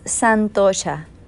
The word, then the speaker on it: Santosha: El segundo niyama que voy a hablarles es Santosha, se escribe Santosa, y esto significa con satisfacción se define como satisfacción, serenidad y paz mental.